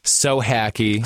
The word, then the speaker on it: Sohacki: Sohacki.